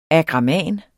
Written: AGRAMANERNES

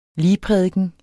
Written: ligprædiken

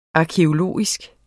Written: arkæologisk

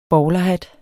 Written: bowlerhat

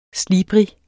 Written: slibrig

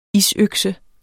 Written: isøkse

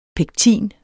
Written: pektin

